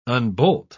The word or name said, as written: unbolt